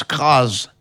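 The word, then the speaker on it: Cras: Et ce nom se prononce crase, avec un Z, SZ, nous précise Michel Fleury, musicologue.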